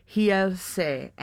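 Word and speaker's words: Realce: Yeah, the R's are H's, right? – Yes. – So I guess it'd be Hielse.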